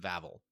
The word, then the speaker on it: Vavel: And the one thing that Vavel rhymes with navel. I don't think I like Vavel. I'm going to go with Vavel.